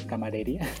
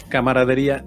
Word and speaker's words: camaradería: tanta tanta buena camaradería, camadería, o cómo se dice, Camaradería. – Camaradería, sí.